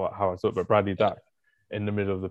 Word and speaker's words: Dack: Bradley Dack. – I always pronounce it as Duck. My bad. But Bradley Dack.